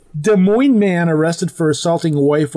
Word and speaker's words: Des Moines: Des Moines man. Did I say it right? – Des Moines. – Yeah, that's what I wasn't supposed to say the S.